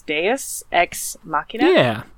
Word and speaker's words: Deus Ex Machina: I believe it's pronounced Deus Ex Machina. – Yeah. It's Latin, I think, too, so there we go.